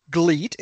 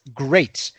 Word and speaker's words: GLEAT: They announced a name for this group that at first looked a little bit goofy because it's spelled GLEAT, which would be GLEAT, but it's not pronounced GLEAT, it's actually pronounced GREAT.